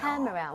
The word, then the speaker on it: camera: She bought me 여기에만 강세 주면 돼요. – camera. 오. – 카메라. 근데 진짜 무슨 이름절인 것처럼 이렇게 돼야 돼요. 카메라. 나눠지는 순간 발음이 엄청 어색해집니다.